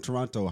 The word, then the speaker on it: Toronto: This guy always pronounces the second T, bro. You always, bro, I hate when you pronounce the second T. This guy, I'm from Toronto. – But like, that is how it's spelled, but like, no one says it like that.